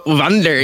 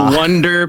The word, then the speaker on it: Wander: The very German way? Wander, yeah. I get like Wonder. People think it's like a U.